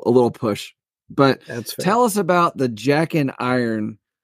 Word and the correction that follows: Jack in Irons: Jack dash in dash irons. – Jack in irons. Okay, like guns in roses.